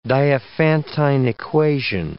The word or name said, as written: Diophantine equation